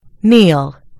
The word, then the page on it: kneel: American English